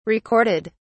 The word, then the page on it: recorded: British English